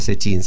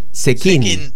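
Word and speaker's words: Zecchin: que además no se pronuncia Sechín, se pronuncia Sequín, ¿verdad? – Claro.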